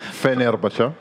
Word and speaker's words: Fenerbahçe: Both teams to score, and also Turkish team, Fenerbahçe, to beat Beşiktaş.